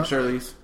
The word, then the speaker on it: Charlize Theron: And second, I have, and I have no idea how you're supposed to pronounce his name, Charlize Theron? – You got it, yeah, Charlize.